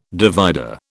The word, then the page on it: divider: British English